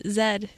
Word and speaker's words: Zed: It's Zed. So it's Zed. It's Z. It's pronounced Z here, and it's a Zed in Canada. And in, like, the UK and...